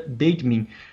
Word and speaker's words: Bateman: O Rashad Bateman, é, fala Bateman, né, mas dei uma, uma confirmada, acho que pronuncia Bateman, da Universidade de Minnesota.